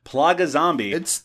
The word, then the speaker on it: Plaga Zombie: Plaga Zombie, and that is the pronunciation we're going to go with. Plaga Zombie. – Not the pronunciation we're going with, that's the pronunciation.